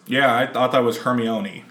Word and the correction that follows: Hermione: Hermione. – See? – Yeah. – See? – Which sounds like a pasta.